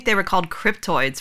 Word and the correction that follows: cryptids: for the longest time. I've called them cryptoids. So they're like, oh, it's cryptids. I've been saying it wrong for 10 years.